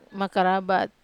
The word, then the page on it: makarabat: wamesa